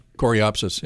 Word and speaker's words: Coreopsis: Then in front of that, maybe some coreopteris. Am I saying that correctly? – Yeah. – Coreopsis, yeah.